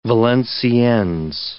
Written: Valenciennes

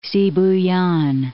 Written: Sibuyan Sea

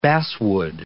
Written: basswood

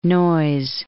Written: Noyes

